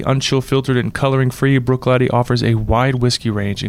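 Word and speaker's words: Bruichladdich: I love Brooklady, and it is pronounced Brooklady, for those wondering.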